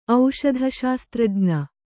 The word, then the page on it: ಔಷಧಶಾಸ್ತ್ರಜ್ಞೆ: auṣadha śastrajña